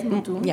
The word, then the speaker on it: Mutum: But going back to Mutum, is that how you pronounce it? – Yes, Mutum.